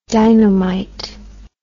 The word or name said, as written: dynomite